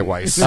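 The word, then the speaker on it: Weisse: It's pronounced Weiss. Weiss, but we're in America, so we're going to say Weiss.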